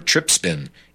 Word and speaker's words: trypsin: from allergies to cancer because of the soy's unusually high trispin inhibitors, or trypsin inhibitors, pronounced correctly.